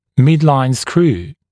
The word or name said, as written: midline screw